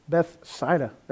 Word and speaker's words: Bethsaida: Philip, we know, lived near Bethsaida.